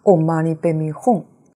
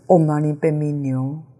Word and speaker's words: Om Mani Padme Hum: 所以有人教他时常忏悔，平时多念观世音菩萨的六字大明咒，但是他把 Om Mani Padme Hum 的咒音念成 Om Mani Padme Nyo，一字之差，但是虔诚专一，持诵了三十几年。